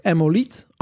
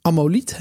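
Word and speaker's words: ammoliet: En Emmolite is eigenlijk Amoliet, Amoliet hè, zoals je het in het Nederlands zou uitspreken is een organische edelsteen die rood en groene tinten heeft.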